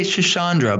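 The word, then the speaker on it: Schisandra: Shisandra. Did I say it right? – Close. That was pretty good. I liked it. We say Shisandra, but you know, tomato, tomato, Shisandra. – There's missing an H in there.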